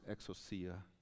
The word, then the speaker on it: exousia: But there's a Greek word in the New Testament that is pronounced exousia.